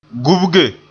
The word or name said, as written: gubge